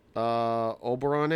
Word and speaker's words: Oberon: I got a Bell's Oberon Ale.